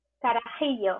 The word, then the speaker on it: Carajillo: the one that I thought was the most interesting, of course, was Cafe Carajillo. Did I say that right? Carajillo. Uh-huh. – Thank you.